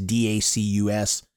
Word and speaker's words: Dacus: However you say her name. – It's Dacus.